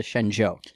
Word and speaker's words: Shenzhou: We were calling it the Shenzhou for a long time, but I guess it's actually pronounced the Shenzhou.